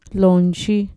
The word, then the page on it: lonchi: Mixtec